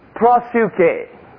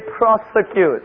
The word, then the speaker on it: proseuche: And the main word for prayer in this book is the word prosuke. Prosuke. Can you say after me? Prosuke. Now look at your friend next to you and say prosuke. Please don't mispronounce it and say prosecute. Right? It's prosuke. And it's spelled P-R-O-S-E-U-C-H-E.